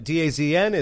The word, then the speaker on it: DAZN: I'm glad you brought up DAZN right off the bat because we've struggled with the pronunciation. – Yeah. Mm-hmm. – It's DAZN. – I've got it now. – DAZN? – Yeah, it is DAZN.